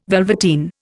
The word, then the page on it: velveteen: Indian